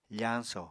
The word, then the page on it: fañso: apatani